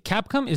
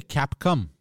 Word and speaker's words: Capcom: Like Capcom is not really good at making trailers, I've noticed. – Did you say Capcom? – Yeah, Capcom. – Capcom, not Capcom. – Capcom. – You pronounce it like C-A-P-C-U-M.